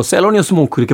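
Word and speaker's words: Thelonious Monk: 예, 그다음에 알토의 루 도나들슨, 피아노의 델러너스 몽코. 이게 뭐 50년대 뭐 비밥을 이끌었던 쟁쟁한 연주자들이 다 모인 음반이 음. 되겠습니다. – 델러너스 몽 스몽크는 우리는 또뭐 셀러니어 스몽크 이렇게 발음을 하시는 분들도 계신데.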